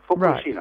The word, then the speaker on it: Fukushima: Fukushima. – Fukushima. – You put in equal emphasis, on each syllable. Fukushima.